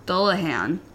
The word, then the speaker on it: Dullahan: and the Irish legend of the Dahollan. – Dahollan? – Yeah. I don't know if... I'm sure James is listening and maybe correcting my pronunciation there, but... – Dullahan? – Dullahan? Oh, yeah. Dullahan.